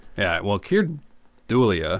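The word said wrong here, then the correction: Dullea: Keir Delay. – Delay? I never always thought it was Dulia, but it's Delay, right? – Yeah.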